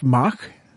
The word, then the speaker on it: Mach: So it's Jeff Mach? – It is Jeff Mac. Like Jeff Mac. – Big Mac truck, or like they said in high school, all together too many times.